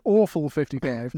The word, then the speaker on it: Orphan 55: And Awful 55. – That's exactly right. I read somewhere that is exactly how you pronounce it.